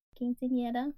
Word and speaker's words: quinceañera: Did I say that right? Anyone? Quinceañera? – Quinceañera, like, but it's when she turns 15.